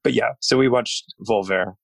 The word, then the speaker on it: Volver: Is Volver by Pedro. – That's how it's pronounced? – I don't know. It seems right. – I think you can hit the R a little harder. Volver. – Volver.